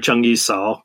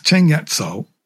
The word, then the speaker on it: Cheng Yat Sao: You use the Cantonese pronunciation in your book. You don't call her Cheng Yi Sao. – No, she's Cheng Yat Sao. – And Cheng Yi is Cheng Yat.